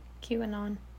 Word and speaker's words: QAnon: Which is also why I don't put any credit towards this allegation either, that they are part of the QAnon. Is that how you say it? – QAnon.